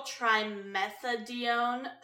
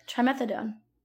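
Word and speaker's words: trimethadione: Which, if administered during pregnancy, can lead to fetal trimethadone syndrome. Maybe. Is the pronunciation. – It looks like it. Trimethadone. – Trimethadone. That I is messing with me.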